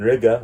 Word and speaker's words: Riga: Guys, I've said this when I was in Riga, which rhymes with nigga.